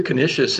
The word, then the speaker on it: Canisius: Saint Peter Canisius, if I'm saying that right, December 21st. – That's right. St. Peter Canisius.